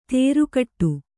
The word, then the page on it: ತೇರುಕಟ್ಟು: tēru kaṭṭu